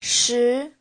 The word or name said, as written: shi2